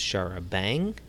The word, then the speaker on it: charabanc: That vehicle usage does suggest a possible link with a name for a form of UK sightseeing bus that is charabanc, pronounced charabang. Derives from the French char à banc, carriages with benches.